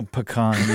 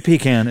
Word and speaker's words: pecan: Pecan. You never say pecan. It's pecan. Got to pronounce it like a Southern boy.